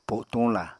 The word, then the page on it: potuñla: apatani